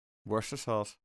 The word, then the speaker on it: Worcestershire: Ehm, en dat is uh, uh, En eh, ga maar lekker dagelijks oefenen met dat uitspreken, want het is echt lastig. Worcestershire. Ja, je schrijft het dus als Worcestershire.